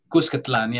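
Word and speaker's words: Cuscatlán: Cuscatlan, yeah. And Cuscatlan. That's actually a Nahuatl word. It's not even a Spanish word. It's a Nahuatl word. It's new Cuscatlan.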